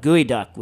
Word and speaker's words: geoduck: The one I'd really like to try is whatever Nathan Myhrvold does for gooey duck, which is, if you're looking it up and you don't know what it is, it's spelled geoduck, and it's the porn star's clam.